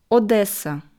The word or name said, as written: Odesa